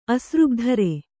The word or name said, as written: ಅಸೃಗ್ಧರೆ